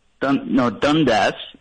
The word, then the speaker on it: Dundas: Dundas is how you say it.